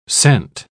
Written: cent

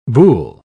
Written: Boulle

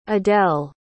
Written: edel

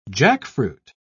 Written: jackfruit

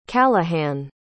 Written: callahan

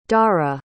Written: darah

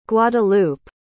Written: guadeloupe

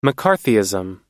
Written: mccarthyism

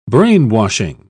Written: brainwashing

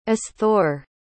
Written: asthore

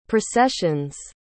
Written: processions

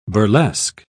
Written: burlesque

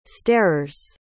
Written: starers

